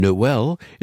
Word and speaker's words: Noel: The old Latin word natalis means birth, and Noel is the French pronunciation that we have inherited in the English-speaking world.